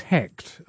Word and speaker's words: Hekt: from Copenhagen, that's Jesper Norbach, who makes music as Hekt. I think that's how it's pronounced, H-E-K-T.